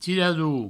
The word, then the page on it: tirador: tiradou